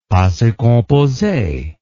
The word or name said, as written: passe compose